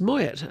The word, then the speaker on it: Moyet: And it's pronounced Moyet, actually. I'm sure it would have originally been Moyet, but no.